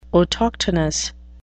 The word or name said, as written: autochthonous